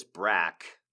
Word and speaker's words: Braque: I'm going to pronounce this Brack, the name Brack. It is spelled B-R-A-Q-U-E.